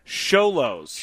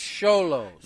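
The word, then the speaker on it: Xolos: Thank you to Alex Lizarraga, and thank you to Dago Brown for saying it's Sholos. – Sholos. – They say, Dago said, pronounced with an S, not a Z or a C.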